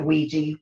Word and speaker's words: Ouija: Okay, so Ouija, which is spelled O-U-I-G-A, I pronounce it Ouija, whether there's another way to pronounce it, I don't know, but it's always been Ouija to me, like W-E-E-G-E-E, Ouija.